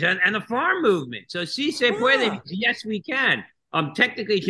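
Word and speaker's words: sí se puede: Is se pueda? – Oh, si se puede, which means yes, we can.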